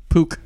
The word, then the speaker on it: Puch: Your word is spelled P-U-C-H. – Pook. – That is correct.